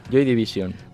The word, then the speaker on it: Joy Division: Joy Division.